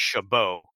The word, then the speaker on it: Chabot: spelled his name the same way, but mm-hmm. pronounced Chabot. So, yes, there is the argument for that, but not to get dragged into that.